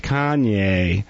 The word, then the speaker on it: Kanye: Say it like the Kardashians say it. Kanye.